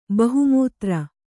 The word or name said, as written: ಬಹುಮೂತ್ರ